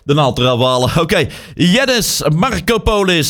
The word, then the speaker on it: Yannis Markopoulos: Mag trouwens niks zeggen, ik kan het zelf ook bij kaas niet uitgesproken krijgen. Yannis Markopoulos. Ja, zo heet hij. Yannis Markopoulos.